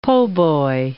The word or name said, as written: po'boy